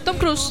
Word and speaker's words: Tom Cruise: Tom Cruise.